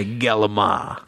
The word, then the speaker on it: calamari: No, I like to say galama. – Oh, galama. – That's the proper pronunciation. – Galama. – If you're real Italian, well, you say galama.